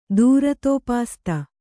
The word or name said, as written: ದೂರತೋಪಾಸ್ತ